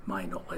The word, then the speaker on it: Mignola: but he says, oh, it's actually Mignola. It's like sign, Mignola.